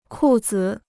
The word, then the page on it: 裤子: kù zi